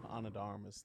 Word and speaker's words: Ana de Armas: And Ana de Armas. – One more time. – That's the one, Ana de Armas.